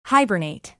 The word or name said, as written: hibernate